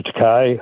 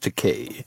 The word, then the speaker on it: Takei: Hi, Mr. Takei. Hi, Mr. Shatner. – Actually, it's pronounced Takei.